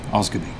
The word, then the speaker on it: Asgabeir: It's spelt A-S-G-A-B-E-I-R. Guess how it's pronounced? Yep, you've got it. Osgoby.